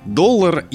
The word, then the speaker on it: доллар: а как правильно, доллар или доллар? И меня это очень удивило, потому что я не думал, что у кого-то вообще может возникнуть вопрос, как ставить ударение в этом слове. Ну вот, например, Михаил Абрамович Штудинер, которого мы каждый выпуск тоже вспоминаем, как и Максима Крангауза, пишет, что доллар, и дает даже запретительную помету в своем словаре, что не доллар.